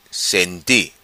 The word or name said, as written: Senti